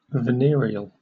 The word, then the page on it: venereal: British English